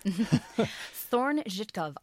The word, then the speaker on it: Thorne–Żytkow: Thorne-Zhitkov objects.